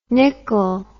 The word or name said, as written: Nicol